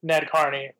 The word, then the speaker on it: Nadkarni: It's Rohan Nadkarni.